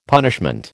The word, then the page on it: punishment: American English